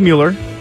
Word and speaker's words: Mueller: It's not Miller, it's Mueller.